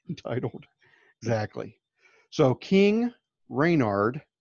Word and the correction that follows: Randor: And then, you know, and then Queen. – I think it's Randor. – Whatever. Randor.